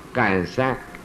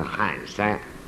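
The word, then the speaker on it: 憨山: 感山。那我们是读惯了喊山，你们现在的国语好像念成感山。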